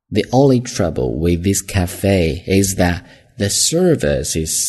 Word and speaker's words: cafe: 因为后面的 only, 第一个音是一个元音发音开始，所以呢前面这个定冠词读 V, the only trouble, O, 双元音嘛 ,with this cafe, 注意啊读 cafe, cafe, 这么去读 ,with this, 这儿两个 th 都是一个浊音咬舌头。